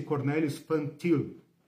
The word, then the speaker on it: Cornelius Van Til: Escreve Van Til. Cornelius, como se fosse um nome latino, né? Van Til. Pronuncia-se Cornelius Van Til.